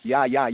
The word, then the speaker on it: ja: we know that in German, the J is not pronounced as a J sound. It's pronounced as a Ja sound. It's pronounced as a Y sound.